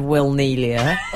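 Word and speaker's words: Wilnelia: She was interesting pronunciation of Will Nelia. – Oh, is it? – Will and Nelia. – You said it as if it was two people, Will and Nelia.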